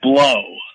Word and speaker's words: Blough: The main source, his name is, it's Joe, and the last name is spelled B-L-O-U-G-H, which is, he pronounced it blow.